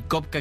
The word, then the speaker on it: Copka: Me encuentran en Instagram y en TikTok como Rob Kopka, como ya dijiste. Roberto es Rob, mi nombre. Y Kopka, que es mi apellido, se escribe con C-O-P-K-A, porque está difícil.